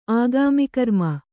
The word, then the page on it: ಆಗಾಮಿಕರ್ಮ: Kannada